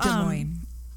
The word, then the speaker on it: Des Moines: So anyway, so Johnny Gosch, he's a son of John and Irene Gosch, and they lived in the suburb of West Des Moines. I'm not sure if I'm even saying that right because I'm from Arizona. So Des Moines.